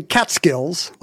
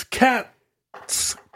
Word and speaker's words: Catskills: So if you're saying Catskills, like I always used to. – It's Catskills. Kills.